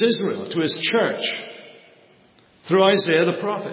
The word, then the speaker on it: Isaiah: Well, we go to the prophet Isaiah, for example. Or as it should be properly pronounced, Isaiah. But Isaiah, because I can speak, I'm bilingual.